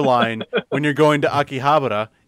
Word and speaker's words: Akihabara: the they asked her to pronounce the words like like Akihabara. Uh, that's the way you would pronounce it correctly in Japanese, but in English you would pronounce it Akihabara.